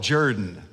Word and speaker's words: Jordan: Actually, they call it Jordan. Judy asked me about that. We were driving through Auburn not long ago, and she said, why do they pronounce it Jordan? It's spelled Jordan. I said, it's an Alabama thing, honey. That's all I know to say. But be respectful and pronounce it like they want you to pronounce it.